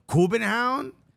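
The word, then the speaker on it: København: Kubenhound? – That's how they pronounce it. – That's how they pronounce the city.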